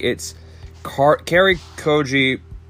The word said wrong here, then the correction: Cary Joji Fukunaga: oh, Cary Joji Fukunaga, yeah.